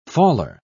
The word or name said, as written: faller